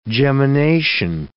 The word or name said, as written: gemination